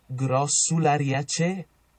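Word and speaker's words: Grossulariaceae: Let me give you the Google pronunciation of that. They like to say chi instead of ca or ce, whichever, but here's the Latin pronunciation according to Google. – Glossulariaceae.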